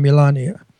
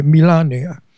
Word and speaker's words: Melania: I pronounce it Italian, Melania. That's how her name is pronounced in her country, by the way, Melania.